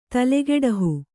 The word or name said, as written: ತಲೆಗೊಡಹು